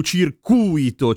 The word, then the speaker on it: circuito: Il circuito è quello elettrico, oppure la pista, e ha l'accento sulla U, circuito. Circuito è colui che è stato circuito, cioè del verbo circuire, imbrogliato, fregato, turlupinato, truffato, fottuto.